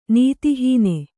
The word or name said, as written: ನೀತಿಹೀನ